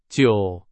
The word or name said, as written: 久